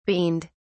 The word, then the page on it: beaned: British English